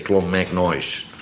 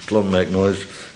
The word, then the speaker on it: Clonmacnoise: Volgens de oude Annals of Clonmacnoise, ik weet niet hoe je dat moet uitspreken, Clonmacnoise, klinkt als noise, hè? Clonmacnoise, komt de naam Ulster, dat is van Noord-Ierland, van Olam.